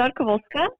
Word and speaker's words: Rzadkowolska: Karolina, oh, by the way, how do you say your last name? – It's Rzadkowolska, but you don't have to worry about it.